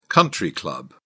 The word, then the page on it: country club: British English